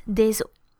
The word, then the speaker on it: os: Os, I said, means bone, and the plural of an os is des os. So it's still the same spelling, O-S, except the pronunciation changes into a closed O. Un os des os.